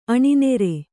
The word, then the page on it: ಅನ್ನರ್: annar